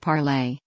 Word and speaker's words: Parler: Breitbart News sat down with John Mazza, the CEO and co-founder of Parler, pronounced Parlay, which describes itself as a non-biased free speech-driven entity.